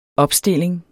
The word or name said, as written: opstilling